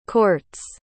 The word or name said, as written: courts